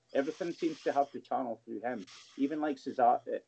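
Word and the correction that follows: Caserio: I always get his name wrong, Nick Casario, is it? – Yeah.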